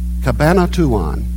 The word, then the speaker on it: Cabanatuan: Christy told me to just pronounce it the way it is. Cabana, Cabanatuan.